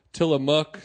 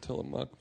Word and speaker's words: Tillamook: It was very delighted that we're trying to work with them, but they also are not delighted that we don't know how to say the word Tillamook. – We figured it out, but yes. – So I got an email from them saying yep. It's called Tillamook.